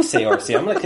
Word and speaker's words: Saoirse: It's Saoirse.